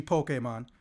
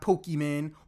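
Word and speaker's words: Pokémon: It's actually Pokemon, not Pokemon or Pokemon. That still annoys me, and it really shouldn't, because I don't even play Pokemon.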